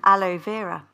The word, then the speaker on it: aloe vera: Aloe vera, yes. – Did I pronounce it correctly? – You did this time.